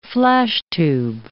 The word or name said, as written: flashtube